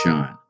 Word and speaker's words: Chan: Well, um, many people are familiar with the term uh, Zen Buddhism. Um, that's the Japanese pronunciation. The Chinese pronunciation is Chan.